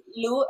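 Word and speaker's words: Lu: So my name is Lou, L-U, and my last name is Colmenares, which is Colmenares.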